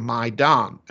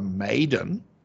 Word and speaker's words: Maidan: If they can't pronounce the Maidan and call it the Maiden, they aren't on top of the Russian-Ukrainian war properly.